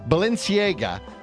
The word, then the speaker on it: Balenciaga: And finally, the online retailer, I hope I pronounced this right, Balenciaga, is selling $950 grocery store bags.